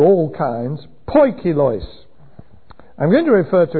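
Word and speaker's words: poikilos (ποικίλος): P-O-I-K, poik, I-L-O-I-S, plural, poikilos. It means dappled, marbled, different strands, varied, all kinds, diverse. And that's how it's usually translated in the New Testament. Poikilos, various kinds.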